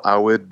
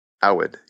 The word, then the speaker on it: Awad: And the last name is like Awad. Awad, yeah.